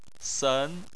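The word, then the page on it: sen: tone 2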